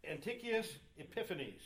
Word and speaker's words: Antiochus Epiphanes: But Antiochus Epiphanes.